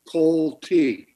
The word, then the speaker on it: Coltey: And I'm going to first of all tell you how to pronounce my name. – Thank you. – My name is Coltey.